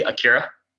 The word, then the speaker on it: Akira: Obviously Akira, if you want to pronounce it correctly.